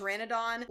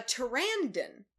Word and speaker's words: pteranodon: Also, he does not call it a pteranodon. Like, he keeps pronouncing it a pterandon.